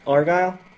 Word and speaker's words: Argyle: Picard, Crusher, and Chief Engineer Arsile? How would you say his name? – Argyle? – Oh. That was a G, sorry.